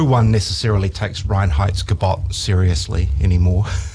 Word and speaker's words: Reinheitsgebot: And you pronounced it perfectly. – The Reinheitsgebot of 1516,